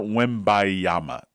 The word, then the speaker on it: Wembanyama: It's Victor Wembanyama. The N is silent.